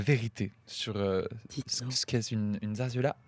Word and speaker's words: zarzuela: Alors, je tiens à dire d'abord que nous avons toutes mal prononcé parce que les deux Z se prononcent S et le U se prononce W. Donc on dirait plutôt zarzuela.